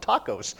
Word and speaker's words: tachos: Really here is, I think it's pronounced tacos. T-A-C-H-O-S.